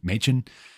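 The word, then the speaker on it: Manchin: I keep saying Machin. I'm reading it how it reads to me, how it looks like it should be said, but apparently it's supposed to be Machin.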